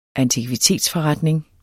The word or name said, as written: antikvitetsforretning